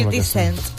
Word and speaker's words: 50 Cent: 50 cent.